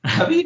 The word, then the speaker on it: Ravi: My first name is easy to pronounce, Ravi. Last name is Kashyap. And I also like to say, if you ever had ravioli the food, you cannot mispronounce my name.